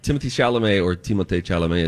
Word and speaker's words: Timothée Chalamet: Timothée Chalamet, as he says his name should be pronounced.